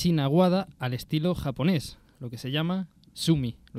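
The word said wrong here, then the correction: sumi-e: ¿Lo he dicho bien, Luisa? – Sumie. – Sumie.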